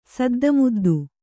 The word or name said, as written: ಸದ್ದುಮುದ್ದು